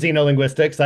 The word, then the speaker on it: Xenolinguistics: Is it Xeno or Xenolinguistics? – I pronounce it Xenolinguistics.